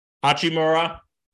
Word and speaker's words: Hachimura: So Hachimura, did I say it right? – Yes.